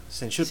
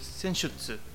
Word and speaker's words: Senjutsu: Senjutsu que, se pronuncia. – Senjutsu. Sí, Senjutsu.